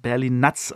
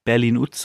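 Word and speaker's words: Berlin Nuts: Und dann hatte ich ja noch den Mackie da und Mackie hat sich auch gemeldet und meinte, du, das spricht man Berlin-Nutz aus. Also ich habe immer Berlin-Utz so gesagt, glaube ich, oder Berlin-Nutz, Berlin-Utz, aber Berlin-Nutz, also es ist englisch ausgesprochen und Nutz halt wie Nüsse.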